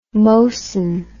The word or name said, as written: mosan